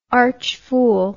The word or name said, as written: archfool